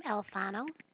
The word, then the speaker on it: Alfano: First name Donna, last name Alfano. – Spell that. – A-L, F as in Fred, A-N-O.